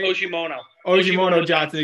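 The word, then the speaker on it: Ogemuno-Johnson: It's been so long, but Ajiri Ajimuno Johnson. I hope I got that right. – Ojimono Johnson. – Excuse me. Ojimono Johnson.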